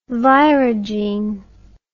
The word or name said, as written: virogene